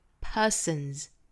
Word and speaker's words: persons: 嗯这个鼻音，鼻音属于是浊音类的，那浊辅音后面再去加 s 或者是加 ed 也好，应该是清清浊浊，浊音后面加的音应该是浊音，所以这里面的 s 应该读 z， persons persons。早读里我经常讲到的这个清清浊浊圆浊是什么意思呢？